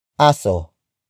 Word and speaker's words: aso: Aso. Aso. Aso is spelled as A-S-O.